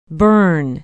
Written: bern

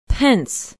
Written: pence

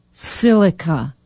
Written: Silica

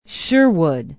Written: sherwood

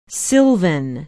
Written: sylvan

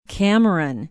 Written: cameron

